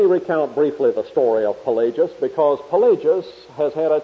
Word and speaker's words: Pelagius: His name is spelled P-E-L-A-G-I-U-S. Pelagius.